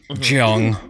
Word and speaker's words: Jung: These people that get so famous, these even Carl Jung, I'm Jung or however you say his name. – Jung. – You know, Jung, exactly. I knew I said it wrong, but I was like, it doesn't really matter, you know? – Because he's not a real person.